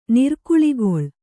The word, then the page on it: ನಿರ್ಕುಳಿಗೊಳ್: nirkuḷigoḷ